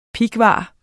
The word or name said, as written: pighvar